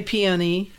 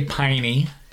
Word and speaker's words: peony: I say peony. – Some people say piney. – People from the northern part of the United States, I often hear peonies.